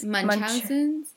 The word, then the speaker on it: Munchausen's: Munchausen's? – Munchausen's, apparently.